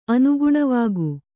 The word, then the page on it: ಅನುಗುಣವಾಗು: kannada